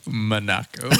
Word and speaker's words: Monaco: I think we've established by now that I'm horrible at pronouncing names. – Monaco. That's perfect.